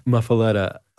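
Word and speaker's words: muffuletta: and muffaletto sandwiches? Muffaletto, did I say that right? – Muffaletta. Italian muffaletta is actually what it says.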